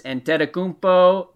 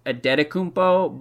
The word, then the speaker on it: Antetokounmpo: And by the way, the pronunciation for Antetokounmpo has changed every single year he's been in the NBA. I had always thought it was Antetokounmpo, but I'm hearing more people pronounce the T's these days.